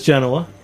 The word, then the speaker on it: Genua: Chris Genua.